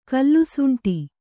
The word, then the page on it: ಕಲ್ಲುಸುಂಟಿ: kannada